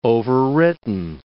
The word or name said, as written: overwritten